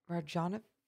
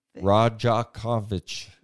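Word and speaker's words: Rajaković: Rajanovic. – Rajakovic.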